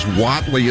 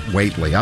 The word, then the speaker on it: Whatley: Says Whatley is pronounced Whatley, not Waitley.